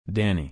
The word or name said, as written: Danny